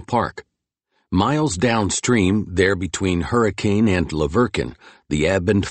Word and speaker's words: Hurricane: He was the water boss in Hurricane, which the locals pronounced Hurricane for reasons no one quite remembered.